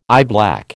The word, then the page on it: eyeblack: American English